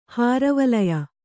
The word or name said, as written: ಹಾರವಲಯ